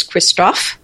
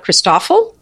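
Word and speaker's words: Christofle: There's Christophe, and Poifocat. The Christophe, people in America sometimes call it Christoffel because of the way it's spelled. – And that sounds awful.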